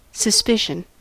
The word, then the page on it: suspicion: American English